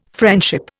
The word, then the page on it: friendship: Indian